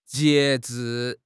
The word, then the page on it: 截至: HSK6